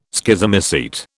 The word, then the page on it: Schizomycete: British English